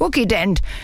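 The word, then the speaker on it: Kukident: Zuzident, 3-Phasen-Reiniger. – Oh, Frieda, das schreibt man mit K, das heißt Cookie-Dent.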